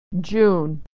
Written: June

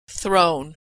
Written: thrown

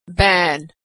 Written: ban

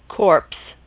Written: corpse

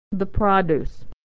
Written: the produce

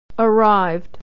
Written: arrived

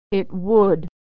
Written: it would

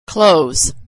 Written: clothes

